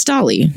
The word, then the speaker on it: Dolly: It's like Wally, but with a D. So it's Dolly, right? – Yeah.